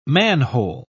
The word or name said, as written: manhole